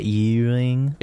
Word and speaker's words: Ewing: I hope I'm saying that right. – Fayette Ewing. – It was E-W-I-N-G.